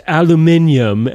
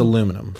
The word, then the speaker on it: aluminum: And then I fucking hate that one more than anything when people are like, aluminium, because it's like, not, you're pronouncing it how it's not spelled. So now you're just fucking it up. It's aluminum. Fuck you, Europe.